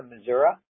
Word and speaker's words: Missouri: You don't have to drive all the way to Missouri. That's how it's properly pronounced.